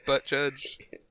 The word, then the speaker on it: Buttigieg: Speaking of runs, what about this Peter Buttigieg guy, right? – Who? – What the fuck are you talking about? – How do you pronounce his last name? Pete Buttigieg? – Buttigieg? Oh, yes. – Buttigieg.